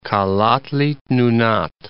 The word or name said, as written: Kalaallit Nunaat